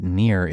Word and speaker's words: Nir: And please note that Nir is spelled N-I-R, like his name.